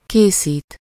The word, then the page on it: fabriquer: France; [fa.bʁi.ke]